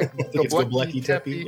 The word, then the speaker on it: Göbekli Tepe: Like Göbekli Tepe, which I may have just butchered. Yeah, Göbekli Tepe. Yeah.